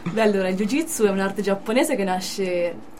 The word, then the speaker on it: Jiu-Jitsu: e sei atleta di Jiu-Jitsu, ho detto bene? – Jiu-Jitsu, sì.